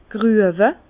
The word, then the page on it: grüewe: Bas Rhin; Herrlisheim